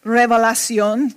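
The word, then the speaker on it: Revelación: In fact, you know, if you speak Spanish, they don't call the book of Revelation Revelacion, that's how you pronounce Revelation in Spanish.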